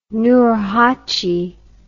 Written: nurhachi

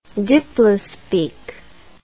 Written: diplospeak

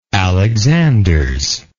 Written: alexanders

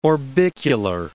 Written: orbicular

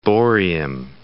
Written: brownfield

